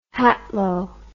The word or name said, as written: hatlo